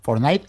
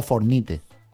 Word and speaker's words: Fortnite: Fortnite o Fornite.